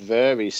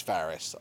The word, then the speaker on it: Varys: I will take a certain amount of mispronunciation of names on this, but his name is Varys, all right? He's not Varys.